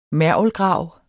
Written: mergelgrav

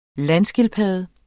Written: landskildpadde

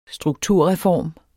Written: strukturreform

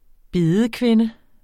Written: bedekvinde

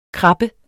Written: krabbe

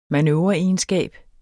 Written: manøvreegenskab

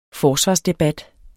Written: forsvarsdebat

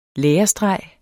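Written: LÆRESTREGER

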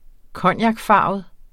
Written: cognacfarvet

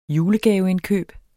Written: julegaveindkøb

